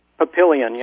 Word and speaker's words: Papillion: Papillion, yes.